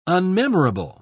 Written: unmemorable

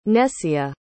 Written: Nessia

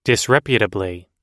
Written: disreputably

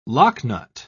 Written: locknut

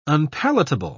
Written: unpalatable

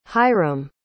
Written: hyrum

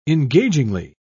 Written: engagingly

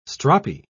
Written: stroppy

